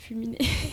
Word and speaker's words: Fulmine: Ça s'écrit Fulmine, en fait. Mais j'ai vu que c'est tourné vers l'Italie. Si on le prononce à l'italienne, c'est Fulminé.